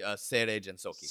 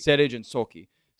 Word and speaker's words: Seirei Gensouki: Seirei Gensouki. Is that how you pronounce it? – It'd be Seirei Gensouki. – Seirei Gensouki.